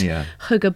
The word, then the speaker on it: hygge: It's the Danish art of hygge, if I'm pronouncing that right. – Hygge.